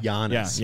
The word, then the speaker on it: Giannis: Soft G. – But yeah. – Giannis.